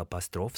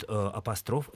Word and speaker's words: апостроф: А вот апостроф все-таки правильно апостроф.